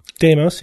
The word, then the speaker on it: Deimos: Mars has 2 small moons, Phobos and Deimos. Is that how you say it? – Deimos, yeah.